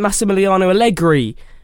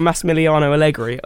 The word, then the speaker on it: Massimiliano Allegri: I mean, even at a moment like Massimiliano Allegri. – Pronounced that well, didn't he? Do you want to say that again? Because that sounded lovely. – What, Massimiliano Allegri? – Oh.